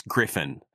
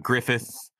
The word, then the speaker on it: Griffin: It's Griffin, not Griffith, not Grissom.